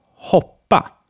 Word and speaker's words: hoppa: And then we have the short o, used in kopp, which means cup. Kopp. And hoppa, which means to jump. Hoppa.